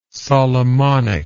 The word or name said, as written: Solomonic